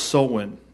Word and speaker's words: Samhain: It's pronounced Samhain, even though it's spelled way differently.